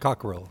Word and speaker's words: Cockrell: He's a research scientist and a lecturer for the Cockrell, is that how do you pronounce it? – Cockrell. – School of Engineering at the University of Texas at Austin.